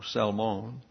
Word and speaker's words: Salmon: In both the Hebrew and the Greek, his word is pronounced with a long O, Salmon.